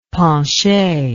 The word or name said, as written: penche